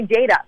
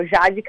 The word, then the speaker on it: Jade: people want to call me Jada because it rhymes with the last name. They want to say Jada Cata-Preta. But, you know, it's just Cata-Preta. It's a Brazilian name. So Jade Cata-Preta is really how it should be.